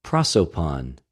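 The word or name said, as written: προσωπον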